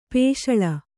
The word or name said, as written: ಪೇಶಳ